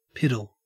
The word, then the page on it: piddle: Australian English